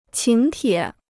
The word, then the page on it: 请帖: qǐng tiě